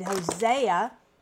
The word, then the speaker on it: Hosea: We did last week, we did Hosea, emphasis on the ho.